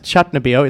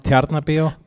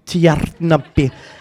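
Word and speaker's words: Tjarnarbíó: It's this coming Saturday, the 28th, at Tjarnabio. It's called Australiana. – It's actually pronounced Tjarnabio. – Tjarnabio.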